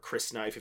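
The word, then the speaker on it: Crysknife: He's going to be the Christ knife, which is really close to Christ knife, or Chris knife, if you want to pronounce it that way.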